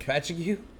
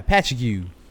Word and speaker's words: Patchogue: Patchogue. – And Portsmouth, New Hampshire. – Patchogue. Patches O'Houlihan. – Yeah. Yeah. I know how that's pronounced. – Yeah, Patchogue.